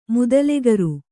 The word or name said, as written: ಮುದಲೆಕರು